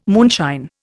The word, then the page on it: moonshine: Indian